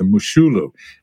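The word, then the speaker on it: Moshulu: But he runs down along the river and he runs past a ship called the Moshalu. So, Maria Jose, you might remember this little tidbit, but any thoughts on the, I'm sorry, the Mushulu, it's pronounced. Tell us about the Mushulu, Maria Jose, and why it's relevant to this podcast.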